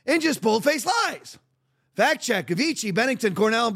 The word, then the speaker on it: Avicii: Avicii. – The next one here. What is it? – Avicii.